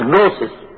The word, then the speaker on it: gnosis: You spell it with G, gnosis, but it is pronounced as gnosis.